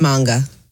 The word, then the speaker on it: manga: It's not a manga? – No, it's manga. – I've been saying it wrong. I did not know that. I guess I've never heard anybody say it out loud.